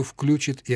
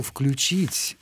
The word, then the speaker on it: включит: А вот в формах личных «включит» или «включит», да, вот здесь уже возникает конкуренция.